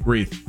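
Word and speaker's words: Reath: Reith.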